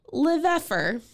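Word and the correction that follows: Lefèvre: Yeah. – Nope. Lefevre.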